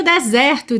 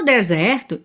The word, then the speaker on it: desert: Bom, o deserto se escreve exatamente como o verbo desertar ou abandonar, D-E-S-E-R-T, D-E-S-E-R-T, mas a sílaba tônica é a primeira e esse E é aberto, então a gente fala desert, desert.